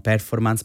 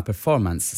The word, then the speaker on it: performance: Allora, c'è qualche problema sì di accento, come sempre, sì, d'accordo, è vero, non performance ma performance, se vogliamo proprio stare alla pronuncia corretta in lingua inglese.